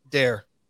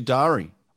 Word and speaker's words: Dare: All right, so how do I pronounce his name? His first name. – Dare. – Just Dare. Is that it? Why do some people say Dari? – Do they say Dari? – Yeah, some people say Dari. – Oh, it's spelled Dare, though.